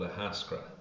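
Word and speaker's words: Ahascragh: Well, we're in a village in East Galway called Ahaskra. – Okay, I'll do my best to keep getting that right as we continue this chat. So. – Well, it's just exactly how it is without the GH at the end.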